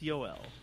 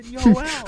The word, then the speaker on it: Joel: It's J-O-E, it's J-O-E-L pronounced Yoel. Or if you saw on Twitter, someone was like, oh, when do we get the not friendly reminder? And Ian was like, it's fucking Yoel.